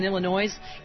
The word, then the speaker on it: Illinois: Oh, Sarah writes, there's no noise in Illinois.